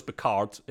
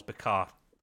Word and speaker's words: Picard: Everyone goes Picard. It's probably not. It's Picard. You barely pronounce the D. You basically pronounce the D. That's the way the French do it, and I love that.